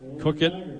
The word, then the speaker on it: Coequyt: So that was number 34, Cook it. He gains about a yard on the play. Cook it. His name is spelled C-O-E-Q-U-Y-T.